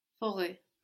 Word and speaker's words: forêt: Good, but would you pronounce that last T? Forêt. Forêt, very good.